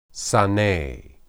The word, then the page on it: sane: hebrew